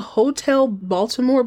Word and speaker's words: Hot l Baltimore: And then in his first and apparently only leading role on a sitcom, he was on this show called, it's called Hotel Baltimore, but the way it's spelled, it looks like Hot L Baltimore, as if the neon sign blew out the letter E.